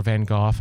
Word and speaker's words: Van Gogh: It's hard to do that with some popular art, you know, and things like that, like Vincent Van Gogh. You cannot think about Vincent Van Gogh or Van Gogh, I guess is how you actually supposed to pronounce that, but everybody says Go.